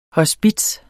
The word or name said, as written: HOSPITSERNES